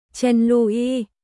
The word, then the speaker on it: Trần Lộ Nhất: Ngày 1 tháng 6, lưu ý, tên của Trần Lộ Nhất, Chen Lu Yi, chữ Lu có âm đọc là Lu, mà chữ Lưu có hai âm đọc là Liu và Lu, nên âm đọc chữ Lưu là âm thứ hai, đồng âm với chữ Lu, cách chương.